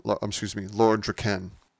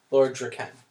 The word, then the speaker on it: Draken: He said that Jason David Frank had said that the proper pronunciation per Kyle Higgins is Lord Dracon. Lord Draken. – Lord Draken. – Yeah, like Ken, like all right. – Ken and Barbie.